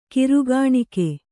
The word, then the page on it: ಗುರುಕಾಣಿಕೆ: guru kāṇike